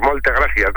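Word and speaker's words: Moltes gràcies: Moltes gracias, no sé si lo digo bien.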